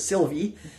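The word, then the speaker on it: Sylva: It's spelled Silva, but they pronounce it Sylvie.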